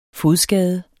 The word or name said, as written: fodskade